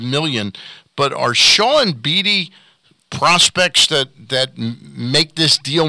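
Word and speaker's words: Beede: is it Tyler Beattie? Is that how you pronounce it? – Tyler Beattie, kid from Vanderbilt, the number one pick from Vandy.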